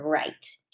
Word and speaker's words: write: So we can't really hear the W in this word right. Can't really hear it. We hear the R. So there's a silent W, and it means we can write our name.